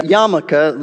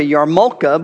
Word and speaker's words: yarmulke: This is known as a yarmulke. The word looks like a yarmulke, but it's pronounced yarmulke.